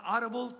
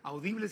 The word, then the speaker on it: Audible: Oigan, pues mañana 4 de junio se estrena en la plataforma Audible, pronunciado en inglés, Audible si lo quieren pronunciar en español.